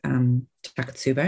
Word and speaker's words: Takotsubo: I only know one person with Takotsubo, am I saying it right? – Yes.